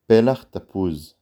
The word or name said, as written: פלח